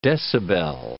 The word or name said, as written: decibel